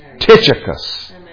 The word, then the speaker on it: Tychicus: It is pronounced Tychicus. Tychicus.